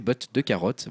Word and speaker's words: Bot de carotte: Donc euh, c'est écrit un peu comme en anglais, Bot to carotte robot de carotte, voilà. Mais bon, ça, ça prononce en français botte de carotte.